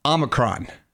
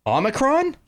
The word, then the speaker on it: Omicron: Omicron. – Omicron? – Yeah. – Omicron. – Yeah, so people call it two different things. They Omicron. – Call it Omicron, and they call it Omicron.